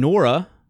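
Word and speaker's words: Nwora: Jordan Wara, I forgot the N is silent.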